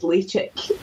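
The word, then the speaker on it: Ledaig: And a guy handed me a mobile phone and it was his pal in Mull, and he said, "Ah, you're not saying it right, it's Lechick."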